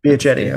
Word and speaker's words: Biagetti: Biagetti, okay.